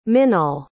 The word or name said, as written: minaal